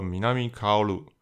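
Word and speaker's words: 南薫: 那还可以读作 Minami Kaoru，名字的由来是他两位喜欢的动画角色。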